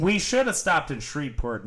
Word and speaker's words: Shreveport: Shreveport.